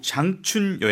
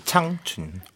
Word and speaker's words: 창춘: 여러분은 지금 EBS FM 니아오차이나 여행작가 전명윤 씨와 함께 중국 장춘 여행을 함께하고 있습니다. 창춘인가요? 장춘인가요? – 창춘. – 창춘. – 우리식 발음으로는 네. 장춘.